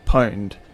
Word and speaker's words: Pwned: One of the best sites we found is called Have I Been Pwned? – And how do you spell that? – It's P-W-N-E-D. – Okay. – So it's like owned with a P.